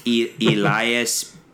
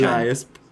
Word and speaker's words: Elias: We should, but I think it's Elias Pettersson. – Elias. – But when I say it like that, I sound like Goldmember. – We couldn't pronounce like McElhaney. – Yes, Elias Pettersson.